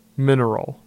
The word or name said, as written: Mineral